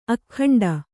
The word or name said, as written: ಅಗ್ಗಂಟು